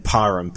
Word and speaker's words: Parham: And Parham, P-A-R-H-A-M. – The H got me there.